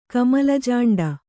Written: ಕಮಲಜಾಂಡ

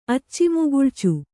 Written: ಅಚ್ಚಿಮುಗುಳ್ಚು

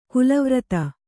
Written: ಕಲ್ಲುವರದ

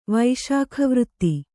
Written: ವೈಶಾಖವೃತ್ತಿ

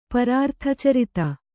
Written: ಪರಾರ್ಥಚರಿತ

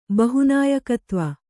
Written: ಬಹುನಾಯಕತ್ವ

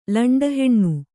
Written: ಲಂಡಹೆಣ್ಣು

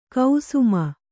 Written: ಕುಸುಮೆ

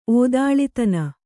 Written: ಓದಾಳಿತನ

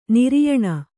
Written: ನಾರಾಯಣ

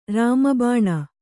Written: ರಾಮಬಾಣ

